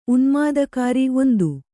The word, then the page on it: ಉನ್ಮಾದಕಾರಿ: unmādakāri